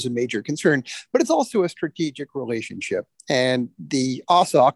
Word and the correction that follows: AUKUS: or however we pronounce this now, strategic relationship reflects the fact. – Awkwardly named AUKUS. – AUKUS. Yes, indeed. And all of this sort of, you know, reflects a reality that the U.S.